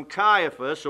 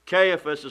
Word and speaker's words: Caiaphas: "Then they led Jesus from Caiaphas," or Caiaphas is also a proper way to pronounce that name.